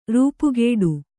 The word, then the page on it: ರೂಪುಗೆಡು: rūpugeḍu